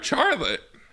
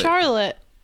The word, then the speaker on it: Charlotte: Charlotte. – Charlotte. – Charlotte. – Charlotte. – It's like an SH sound.